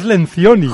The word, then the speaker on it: Lencioni: Patrick Leoncini. – No, es Lencioni.